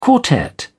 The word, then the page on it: quartet: British English